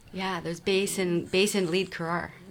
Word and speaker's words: krar: Yeah, well, you know, it is really cool to hear Kwankwa's recordings and to hear the sound of the Carrar, because Carrar, so you actually almost have a two-syllable word there, right? – Yeah. – Carrar. – Yeah, sometimes it's spelled K-I-R-A-R and sometimes it's spelled K-R-A-R.